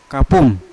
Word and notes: kapum: Yawa

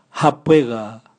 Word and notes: habwega: Raga

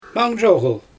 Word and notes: manzhoGyl: Xyzyl

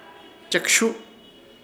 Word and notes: Chekshuhu: Ishir Chamacoco

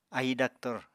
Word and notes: ahi daktor: Apatani

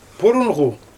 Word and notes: purunGu: Xyzyl

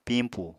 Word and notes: pimpù: Apatani

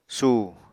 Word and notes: su: Apatani

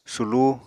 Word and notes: sulú: Apatani

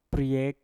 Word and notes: priek: Mpur

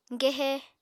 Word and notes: gehe: Apatani

